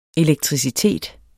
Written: elektricitet

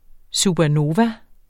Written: supernova

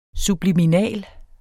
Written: SUBLIMINALT

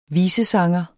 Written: visesanger

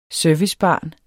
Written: servicebarn

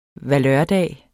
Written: valørdag

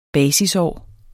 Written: basisår

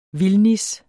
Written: vildnis

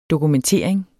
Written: dokumentering

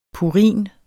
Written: purin

